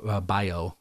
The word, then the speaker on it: Bello: Another pitcher that did as well was Brian Bello with the Boston Red Sox, B-E-L-L-O. Bio. I believe that's how it's said, not Bello, but Bio.